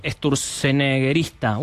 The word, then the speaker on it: sturzeneggerista: No es un sturzeneggerista. Uf, salió, ¿eh? Difícil, mira. Pero ha salido. Me valida Giselle Tepper acá a mi lado, me dice que lo dije bien.